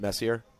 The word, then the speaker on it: Messier: Michael, if you really want to impress Messier, by the way, you know he prefers to be have his name pronounced Messier.